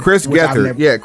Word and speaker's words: Gethard: Chris Gethard. Chris Gethard. He was part of the Vice. – Pause, because Jim Cornette read it as Chris Gethard. – Because it's spelled Gethard.